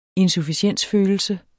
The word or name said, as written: insufficiensfølelse